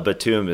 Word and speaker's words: Batum: Nicolas Batum is how the French would say it.